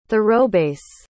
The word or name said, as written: thoroughbass